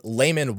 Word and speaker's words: Luman: But there's also someone closely tied to the Smith family called Laman Walters. It's spelled L-U-M-A-N, but it was pronounced as Laman and sometimes written as Laman, L-A-M-A-N.